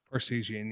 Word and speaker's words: Parseghian: And then the Wildcats kind of returned to prominence under head coach, and I'm going to absolutely butcher this name, Ara Parseghian. – Parseghian, yeah.